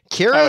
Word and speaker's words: Kira: Pronounce the Kira, Kira, Kira is how it's pronounced? – So. Yeah.